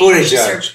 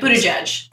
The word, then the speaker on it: Buttigieg: Buttigieg. I can actually say his name. – Buttigieg. – Buttigieg.